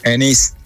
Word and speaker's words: Enix: ¿Qué es, Enix o Enix? – Enix. – Enix, vale. – Lo digo bien, ¿no? – Lo has dicho bien. – Terminado en X.